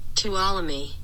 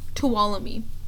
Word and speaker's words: Tuolumne: I love the way I pronounce these streets. Tulalumine. Okay. Tuolumne. Tuolumne. At uh,